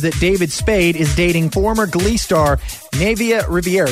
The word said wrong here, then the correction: Naya: Did I say her name right? – I think it's Nivea. – Nivea. It's Naya, isn't it? – Naya. No, Naya. – N-A-Y-A.